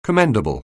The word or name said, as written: commendable